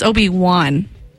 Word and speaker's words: Obi-Wan: It's why is it say, why you say one? It's Obi-Wan. – Obi-Wan Kenobi. – I know, Obi-Wan. – Obi-Wan. Help me, Obi-Wan. W-A-N.